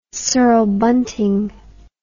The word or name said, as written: cirl bunting